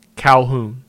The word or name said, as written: Calhoun